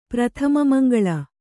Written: ಪ್ರಥಮಮಂಗಳ

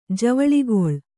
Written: ಜವಳಿಗೊಳ್

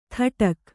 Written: ಥಟಕ್

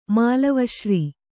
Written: ಮಾಲವಶ್ರೀ

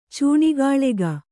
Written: ಚೂಣಿಗಾಳಗ

